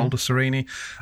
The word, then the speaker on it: Baldessarini: Is that how you pronounce it? Baldessarini? Mm-hmm. Baldessarini.